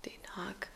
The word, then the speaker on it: Den Haag: Oder beziehungsweise der Ort De, De Haag? Spricht man so aus? Ja, De Haag. Den Haag. De Haag.